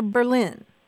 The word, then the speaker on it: Berlin: Is that Berlin or Berlin? – You know they pronounce it Berlin, like rhymes with Merlin.